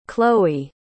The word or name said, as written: Kloe